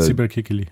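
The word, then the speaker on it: Sibel Kekilli: Martin trifft auf Sybil Kikili. Heißt sie so? Wird sie so ausgesprochen? Ja, also äh, Sybil Kikili.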